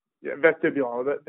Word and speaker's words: vestibular: the vestibulus? Is that the, did I say it right? – Vestibular.